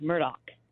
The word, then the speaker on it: Murdaugh: Jessica, is it pronounced Murdoch or Murdaugh for this gentleman? – Well, if you hear him say his own name, he pronounces it Alec Murdoch.